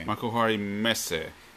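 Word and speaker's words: Makuhari Messe: the Makuhari Mesa. – Let me see, because I usually help you with this. – Yeah. – Makuhari. – Yeah, okay. – Makuhari Mesa.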